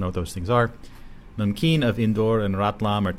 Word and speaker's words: namkeen: Namkeen is pronounced numkeen. Okay, I've been pronouncing it wrong. Numkeen. Well, there's an A instead of a U, but we'll call it numkeen.